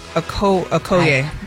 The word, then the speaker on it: Okoye: Okuyoke? O-K-O-Y-E? – Oh, my God. Did anybody say it right? – I think it's Maduka Okoye.